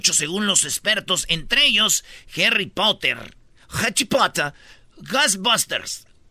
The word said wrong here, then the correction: Ghostbusters: Ghostbusters, Ghostbusters.